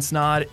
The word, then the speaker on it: Snaadt: My name is Ryan Snod. It rhymes with odd, and welcome to the Rhymes with Odd podcast.